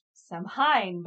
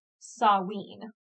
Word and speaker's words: Samhain: because I always thought it was Samhain, but it's actually Samhain.